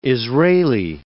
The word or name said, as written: Israeli